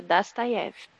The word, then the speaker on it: Dostoiévski: A curiosidade que já falou para mim milhões de vezes é Dostoiévski. Porque o O se pronuncia como A, não é isso não? – Ah, ele fez literatura Dostoiévski.